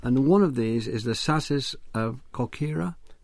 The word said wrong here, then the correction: Corcyra: I pronounce it Corcyra, but Keck Thank you very much. Yep. Well, I'll pronounce it Corcyra from now on.